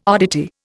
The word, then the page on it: oddity: Indian